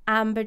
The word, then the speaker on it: Ambar: Yeah, you can follow me on Instagram at Amber Driscoll. It's AMBAR. Because it's spelled a bit differently. Amber Driscoll.